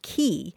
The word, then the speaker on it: quay: And so I thought I'd give you guys a call and so, yeah, the word spelled Q U A Y is usually pronounced key. It came into English in the 1300s uh, spelled K E Y, but then it was changed uh, to the French spelling, which was Q U A Y.